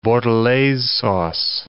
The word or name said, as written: bordelaise sauce